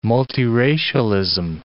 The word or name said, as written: multiracialism